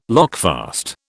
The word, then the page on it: lockfast: British English